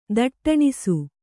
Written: ದಟ್ಟಣಿಸು

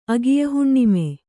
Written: ಅಗಿಯಹುಣ್ಣಿಮೆ

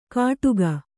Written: ಕಡುಗ

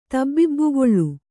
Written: ತಬ್ಬಿಬ್ಬುಗೊಳ್

